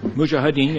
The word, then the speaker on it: Mujahideen: the Mujahideen, if I'm saying it correctly? – Mujahideen, yes.